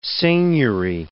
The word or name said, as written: seigneury